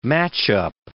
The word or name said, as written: matchup